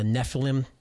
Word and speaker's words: Nephilim: So, and the origin of the Nephilim. – Mm-hmm. – If I pronounce that correctly? – Yes, you can pronounce it that way.